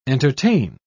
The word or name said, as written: entertain